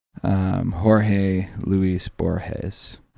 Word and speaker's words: Jorge Luis Borges: We're going to talk in the second segment about Borges's, we're going back to that collection of just treasures, those stories by Jorge Luis Borges. Am I doing that right? – Yeah, very good.